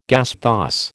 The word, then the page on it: gasthaus: American English